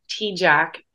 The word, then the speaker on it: Tejcek: It's pronounced T-Jack, but it's not spelled like that. So on Instagram, you can find me at Rye, R-I dot T-E-J-C-E-K.